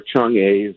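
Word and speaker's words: Chang'e (嫦娥): So this is the Chang'e 4, or Chang'e, if you pronounce it the Chinese way, 4 lander that landed on the far side of the moon, not the dark side.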